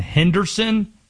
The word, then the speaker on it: Henderson: You want to know how the people in Henderson pronounce it? Henderson. Two syllables. Henderson.